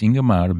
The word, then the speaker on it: Ingmar: What happened to me years ago, I was talking about the actress Ingrid Bergman or the director Ingmar Bergman. And everyone was calling him Ingmar Bergman. And I was corrected. No, it's Ingmar Bergman.